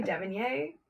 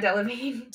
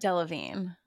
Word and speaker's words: Delevingne: I always want to say Devonier, but it's like not that. Cara Delevingne. – Delevingne.